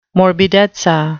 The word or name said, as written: morbidezza